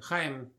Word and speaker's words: Chaim: Chaim, what? – Chaim. – Chaim. – Yeah.